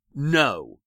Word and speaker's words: know: In the next presentation, where I'll discuss a linguistic principle called Grimm's Law, you'll see that the Greek base gno or gnos, G-N-O or G-N-O-S, spelled with an initial G, is cognate with our word know, as in you know, the know that starts with a K, which is now a silent letter.